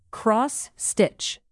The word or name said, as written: cross-stitch